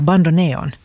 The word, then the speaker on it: bandoneon: Och det som är intressant här, som vi ska prata med Henrik Sandås, är med det här Bandoneon. – Bandoneon, du måste utveckla det här. – Ja, nu ska jag säga, jag tror att du uttalade rätt, bandoneon.